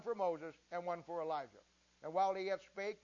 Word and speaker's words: Elijah: Now don't worry about the way it's spelled there. That's the difference between coming from the Hebrew to the English and then the Greek to the English. It's Elijah.